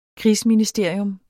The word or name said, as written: krigsministerium